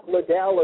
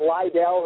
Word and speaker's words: Liddell: Chuck Liddell, or some people are mispronouncing it, Liddell, who aren't big MMA fans.